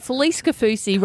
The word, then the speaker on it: Felice Kafusi: Felice Kafusi rhyming with sushi.